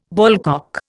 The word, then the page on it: ballcock: Indian